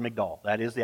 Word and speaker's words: Migdal: The Hebrew word for tower there is, make sure I say it right, Migdal, M-I-G-D-A-L.